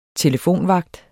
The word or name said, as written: telefonvagt